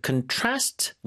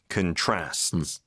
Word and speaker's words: contrast: 자, contrast with 뒤에 강세를 주로 많이 주고 있습니다. 발음이요. – Contrasts. – 동사 읽을 때는 네. – 이게 명사일 땐 contrast 앞에 주거든요. – Yeah. – 동사일 때는 강세가 뒤로 넘어간다는 거.